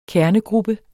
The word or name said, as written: kernegruppe